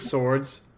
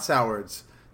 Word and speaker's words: Sowards: It's two swords. It's not Sowards. It's two swords.